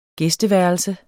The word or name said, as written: gæsteværelse